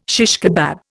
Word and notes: shish kebab: Indian

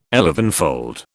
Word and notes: elevenfold: British English

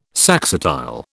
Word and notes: saxatile: British English

